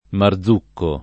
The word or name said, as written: Marzucco